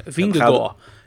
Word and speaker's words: Vingegaard: Maar ja goed, Vingegaard is natuurlijk op hoogtestage geweest. – Jij wilt deze uitspraak aanhouden? – Ja, maar dit is hem. Zo moet je het uitspreken. Vingegaard.